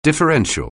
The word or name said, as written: differential